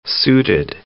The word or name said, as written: suited